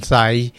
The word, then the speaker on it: Thai: Yun nga palang Thai, T-H-A-I, hindi pinopronounce yung H.